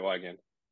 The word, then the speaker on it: Weigand: Yeah, that's right, Wiegand.